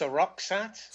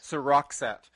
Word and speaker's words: Seroxat: So, By the way, it's pronounced Seroxat. – Seroxat. – Yeah. – Okay, I'm always bad with where the stress goes in words.